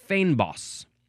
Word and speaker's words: Fynbos: According to the pronunciation, it looks like it is pronounced Fynbos.